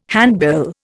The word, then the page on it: handbill: Indian